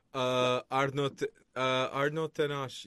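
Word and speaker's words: Arnau Tenas: Arno Tenash for Barcelona. – Arno Tenash. Yeah, I think you said it right.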